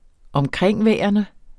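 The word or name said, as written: omkringværende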